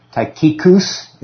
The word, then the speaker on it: Tychicus: But that you may also know my affairs and how I do I think the emphasis is on the third syllable. Tychicus is the right pronunciation.